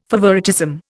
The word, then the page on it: favouritism: Indian